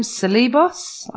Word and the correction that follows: Ceballos: I don't know. – Ceballos. – Oh, Ceballos. I get it all wrong. – Ceballos, yeah.